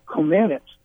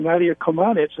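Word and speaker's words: Nadia Comăneci: And the last thing that they did on the Olympic coverage that night, Jim McKay said, say your name, see Comaneci. Meanwhile, I'm coming up with Comanets. And about 2 minutes later, I'm on saying Nadia Comaneci is one gold medal in gymnastics.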